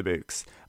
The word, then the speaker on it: Bouqs: Boaks, B-O-U-Q-S. – Yeah, Boaks. Like bouquet, but without the other part of Boaks.